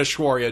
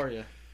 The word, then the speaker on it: Aishwarya: It's not Aishwarya Rai, right? – Oh, I love Rai.